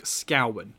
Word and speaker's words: Scowen: So my name is Luke Scowen. Like Cowen. Luke Scowen. Cowen. The amount of people that pronounce it Scowen. It does my nuts.